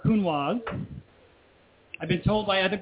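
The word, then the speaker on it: Counoise: If you go read the literature, if it has that E on the end, then you pronounce the vowels, and so it's Cunoise.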